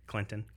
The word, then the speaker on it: Clinton: It's where the T is sweet and the T is silent. I don't know if people still make that joke or not, but Clinton.